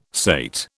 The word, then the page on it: sate: British English